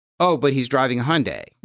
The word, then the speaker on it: Hyundai: Apparently it says it's pronounced Hyundai. – Hyundai. – Like Sunday.